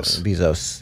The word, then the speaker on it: Bezos: And so we really applaud Jeff Bezos. I hope I'm pronouncing his name right. – Bezos. – Yeah, yes. – The S is not silent.